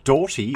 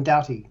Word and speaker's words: Doughty: D-O-U-G-H-T-Y, sounds like an Irish name, and I'm Irish and I can't even pronounce that. Tell me about Caitlin Doughty, or Doughty, how do you pronounce it, and who is she? – Caitlin Doughty. Caitlin Doughty. – Doughty, thank you.